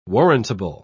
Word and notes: warrantable: British English

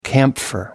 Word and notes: camphor: American English

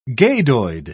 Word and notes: gadoid: British English

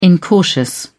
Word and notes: incautious: British English

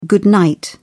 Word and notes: good night: British English

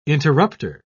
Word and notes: interrupter: British English

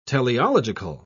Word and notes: teleological: British English

